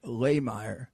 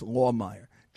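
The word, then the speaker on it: Lahmeyer: I was campaigning for a candidate for the Senate, Jackson Lehmeyer, Jackson Lawmeyer. It's L-A-Y, but so it's when you spell it, it sounds like Lehmeyer, but it's pronounced Lawmeyer.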